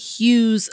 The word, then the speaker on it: Hughes: So when I say hues, I am referring to the different hues of black people. When I say hues for a second time, which is spelled H U G H E S.